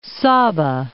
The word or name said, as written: Saba